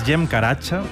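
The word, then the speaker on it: Cem Karaca: Este es Jem Karacha, que se escribe Zem Caracas, si lo queréis buscar.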